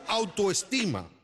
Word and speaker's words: autoestima: Sí, alta esquina. ¿No lo dije bien? – No, no. A ver, diga conmigo. – Conmigo. – No sea tolete. Diga conmigo autoestima.